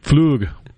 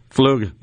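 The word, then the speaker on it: Flügge: I don't know how to spell it, but that's it may be P-F-L-U-G, I guess, possibly. – It's F-L-U with the little umlaut G-G-E. Okay. – So that would be the sort of the German. – Flug. – Flug.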